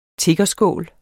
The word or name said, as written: tiggerskål